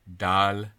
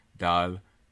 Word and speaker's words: dal: The people who have modeled it for me who are South Asians always sound to me like they're putting a little, almost like a secondary unstressed syllable at the beginning, like Dahal. Yeah, Dahal. I then try to do that, and they go, no. No, you're not doing it right.